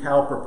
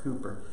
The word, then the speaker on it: Cowper: William Cowper put it this way, or Cooper, I think you pronounce it Cooper, but it's spelt like Cowper.